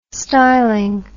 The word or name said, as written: styling